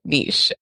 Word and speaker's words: niche: It's a French word, and that's how you say it. Niche would be spelled N-I-T-C-H. And the word is not spelled that way. So we say niche.